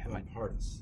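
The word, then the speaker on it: Van Parijs: His name is Luc von Riesch, I think. – Von Paris. – Von Paris, yeah. I'm sorry, pronounced that wrong.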